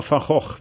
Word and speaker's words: Van Gogh: No, Van Gogh, we say.